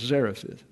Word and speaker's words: Zarephath: The reason I put the accent on the A is because for years I pronounced that as Zarephath, and it's not, it's Zarephath.